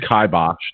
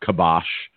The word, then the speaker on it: kibosh: Well, first of all, Mr. Saginaw Valley State, as I stare at our show notes here, it's kibosh, not kibosh. Okay. And it's spelled K-I-B-O-S-H.